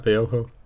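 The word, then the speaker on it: Biohó: Spelled B-I-O-H-O. – Biojo. – It depends where it's from. If it's like in the Spanish language, then that's right, Biojo.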